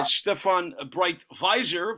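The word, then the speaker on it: Stéphane Breitwieser: Stefan Breitweiser, if we want to use the proper Alsacian pronunciation, and Catherine Kleinklaus, who, if I remember from reading the book correctly, managed to steal around $2 billion worth of art.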